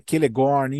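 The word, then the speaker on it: Celegorm: Tá, é, isso, o início do, da letra C, o Tolkien, ele colocou a letra C como, como se fosse a letra K, ou como se fosse o Q e E. – Então é Celeborn, Kirdan, é Kelegorn, enfim, tem todo, toda, toda. – É só um é, ajuste de pronúncia.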